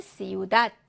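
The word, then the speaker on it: ciudad: 그래서 앞 단어는 시우닷 해서 맨끝 음절에 오고요.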